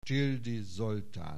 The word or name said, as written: Tildy Zoltan